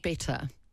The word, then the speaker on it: better: I hate the T's to the D's. – Yes. – That's not how you say it. – No, better. There you go.